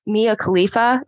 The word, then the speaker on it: Mia Khalifa: It's also so bad that Mia Khalifa, isn't that how you say her name, the porn star? – Sure. Yeah.